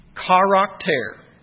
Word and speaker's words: charakter: The term express image translates the Greek word pronounced, and you can tell what English word it is, charakter.